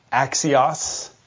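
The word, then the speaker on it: axios (ἄξιος): The word worthy, it's pronounced something like axios in Greek.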